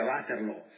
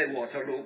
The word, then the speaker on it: Waterloo: Waterloo è un villaggio, io lo pronuncio così, che più o meno è la pronuncia fiamminga, Waterloo, a qualche chilometro dal campo di battaglia. Non si è combattuto lì, ma lì aveva dormito il duca di Wellington. È da pronunciare, cioè pronunciandolo sbagliato all'inglese, Waterloo, come facciamo a volte anche noi, viene facile per le loro bocche.